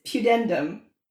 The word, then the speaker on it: pudendum: It's pudendum.